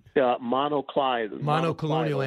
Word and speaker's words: monoclonal: So I talked to the West Virginia governor, and he wanted more of those mononuclear antibodies. Did I say that right? No, I didn't. – Monoclonal antibodies.